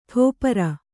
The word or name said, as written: ಟೆಪ್ಪರ